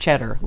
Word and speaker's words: Tschetter: If you don't know that name, it's spelled T-S-C-H-E-T-T-E-R, but it's pronounced Cheddar, like the cheese.